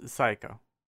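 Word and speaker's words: Saeko: It's Psycho.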